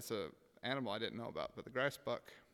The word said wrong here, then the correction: grysbok: Um, and I can't say it like they do. They have a really cool way of saying it. grysbok. Yeah, there you go.